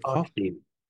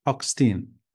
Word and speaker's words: Hochstein: Is it Hochstein? – Hochstein.